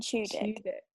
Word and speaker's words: Tudyk: and then, of course, you've also got, how would you say his surname? Oh, I'm going to mess this up. Alan Tudyk. Alan Tudyk.